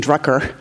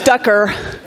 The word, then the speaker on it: Ducker: I kept saying Drucker. I know it's Ducker.